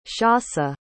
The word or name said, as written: shasa